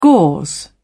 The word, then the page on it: gauze: British English